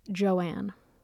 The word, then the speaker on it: Joan: On her birth certificate, her name was Joan, J-O-A-N, but she preferred to pronounce it Joanne.